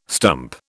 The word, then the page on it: stump: British English